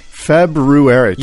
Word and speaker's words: February: February. – You just slipped it in there. – Tough word to say. – Tough, but you said it correctly.